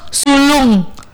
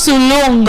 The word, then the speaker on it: Solong: Solong tuh bacanya gini, lurus sulung. Solong tuh sulung.